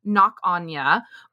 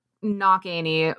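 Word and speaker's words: Cnoc Áine: And her hill, which is Knock Anya or Knock Annie, which is the anglicized version of that. Knock Anya is actually like the Irish version of it.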